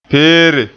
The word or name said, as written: peere